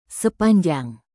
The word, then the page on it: sepanjang: Indonesian